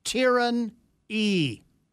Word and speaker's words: Tyrane: Tyrane, T-Y-R-A-N-E, pronounced Tyrone E.